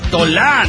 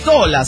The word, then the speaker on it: Tola: Oye, pinche pirata, cabrón, no mames, deja de pronunciar Tola. No te sé el acento en la A. La pronunciación es Tola, sin acento.